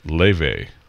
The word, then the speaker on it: Laufey: It's spelled L-A-U-F-E-Y, but in whatever that language is, Viking language, it's pronounced Leve.